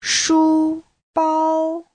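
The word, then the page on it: shubao: shu1bao1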